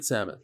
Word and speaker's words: Samet: How do you pronounce it? Samet. No, in Hebrew it's Samet. It's Hebrew. Samet.